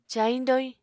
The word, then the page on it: cayiñ-doyiñ: apatani